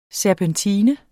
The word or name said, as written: serpentine